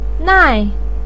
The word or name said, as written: nai4